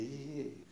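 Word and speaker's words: Yehiyeh (אהיה): Yeah, the Yehiyeh. That's how you say it, the Yehiyeh, the eternal.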